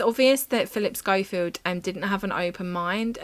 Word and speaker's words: Schofield: Where Philip Schofield. – Yeah. – Is that how you say it? Schofield? – Schofield. – Schofield. Yeah. Okay. Philip Schofield. Yeah, that sounds right.